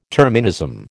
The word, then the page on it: terminism: American English